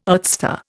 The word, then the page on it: earthstar: Indian